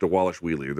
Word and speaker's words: Dvalishvili: I'm gonna, sh- I'm sure I'm fucking his name up, but they say on the broadcast Dawalish Wheelie. There's a V there, but it, they pronounce it like a W. Seven fight win streak for Dvalishvili.